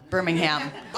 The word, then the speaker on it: Birmingham: Birmingham. – Yeah. – I said that wrong. I said it like it was Alabama. Birmingham.